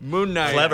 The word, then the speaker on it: Knight: Yes, Moon Knight is a superhero. – Knight with a K. – Yeah, Knight with a K. – Is silent. – Moon Knight.